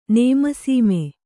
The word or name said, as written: ನೇಮಸೀಮೆ